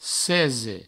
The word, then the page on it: cese: provençal